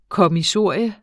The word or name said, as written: kommissorium